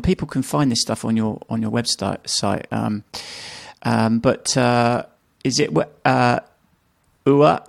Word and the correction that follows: Eva: is it, how do you pronounce her name, the lady? Ua and Piotr. – Eva and Piotr.